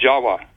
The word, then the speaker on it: Java: Furthermore, appending the suffix of T-I-S, the word Atlantis, it also is spelled T-I-V or pronounced T-I-W. The V's are pronounced as W's in Dravida, such as in Java. The word in Java, the island Java, you know, is pronounced Jawa. Actually, Indonesians will pronounce it Java, not Java like we do.